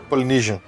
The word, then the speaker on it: Polynesian: No Polynesian? Como é que fala? – Polynesian.